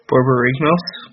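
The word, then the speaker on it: Borborygmos: Borbi Yorgamos? I don't know how to pronounce his name, but the classic Gristlebrand All-Star. – Borbi Yorgamos? – Yes, that sounds quite right.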